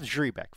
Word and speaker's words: Zrebiec: Jeff, I never know how to say his name. Zrebeck? – Yeah, that sounds good. – All right. Jeff Zrebeck.